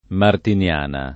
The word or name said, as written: Martiniana